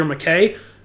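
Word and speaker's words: MacKay: I actually looked up the pronunciation because I wasn't sure if it was Mackey or McKay, and I think it's McKay.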